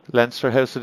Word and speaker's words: Leinster House: Is it Leinster House? Leinster House? – Leinster House it is indeed, yes.